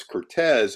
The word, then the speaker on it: Curtiz: So everyone in Hollywood, including his best friends and his closest co-workers, called him Curtiz. So I call him Curtiz, but how he pronounced his name was Cortez.